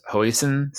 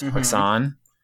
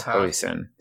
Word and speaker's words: hoisin: Can I share with you that I learned that it is pronounced hoisin sauce? I thought it was hoisin, but it is not. It's hoisin.